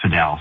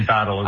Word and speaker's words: padel: And in the Spanish language, it's pronounced padel. So I guess that's the way it should be pronounced, but a lot of people say paddle as well. So either one works.